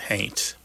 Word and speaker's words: haint: Somebody might spell it haunt, but pronounce it haint.